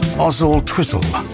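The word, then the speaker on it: Oswaldtwistle: Gordonstone says, Louis, it's pronounced Ozzle Twizzle without the W.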